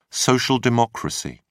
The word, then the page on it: social democracy: British English